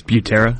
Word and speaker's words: Butera: Brueta? How do you say it? Say it again. – It's Butera. – Butera.